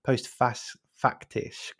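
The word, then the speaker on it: postfaktisch: and I'll even try and pronounce it in German, post-faktisch was the German word of the year in 2016.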